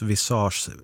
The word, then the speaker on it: visage: PS, visage uttalas visage, ansikte på franska.